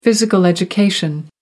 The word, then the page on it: physical education: American English